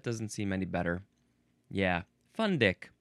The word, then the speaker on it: fundic: Next is fundic. Just gonna let that sit there for a second. That seems like how it is pronounced. Fundic, spelled F-U-N-D-I-C.